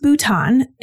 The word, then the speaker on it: Bhutan: It's pronounced Bhutan,